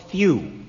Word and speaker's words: thew: Well, it's actually called thew. Thew is the proper pronunciation, I believe.